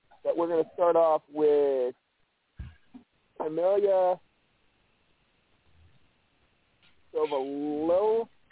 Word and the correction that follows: Saviola: Hard to pronounce that. – I think it's Saviola. Yeah, Camille Saviola.